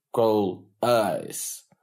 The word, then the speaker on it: Калаис: Местные произносят «Калайс» как «Кол-Айс».